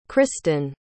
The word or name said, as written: kristan